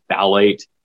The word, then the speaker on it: phthalate: So one big one, for instance, that's a carcinogen in an endocrine disruptor is the phthalate. It's spelled very funny. The P is silent.